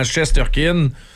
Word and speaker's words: Shesterkin: Alors qu'on l'américanise en disant Shesterkin parce que c'est plus facile.